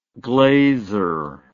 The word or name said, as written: glazer